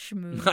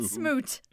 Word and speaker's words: schmooze: I forgot the CH there. – It's pronounced schmooze. – I know. – And also, honey, it's not smoot.